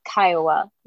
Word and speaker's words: Kiowa: I did have to double check this because I didn't actually know how to pronounce it, but it's Kiowa. That's how they say it. – Kiowa, yeah.